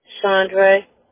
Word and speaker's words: Chaundrae: Chandra. – How do you spell that? – C-H-A-U-N-D-R-A-E.